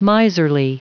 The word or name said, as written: miserly